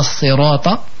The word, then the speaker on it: الصراط: Faites l'effort de prononcer les lettres de la manière par laquelle le verset a été révélé, comme par exemple ihdina as-sirata, parce que as-sad ou sin, c'est pas la même chose. As-sirata al-mustaqim, as-sirata, il faut un peu avancer la mâchoire inférieure pour pouvoir prononcer la lettre as-sad comme il faut.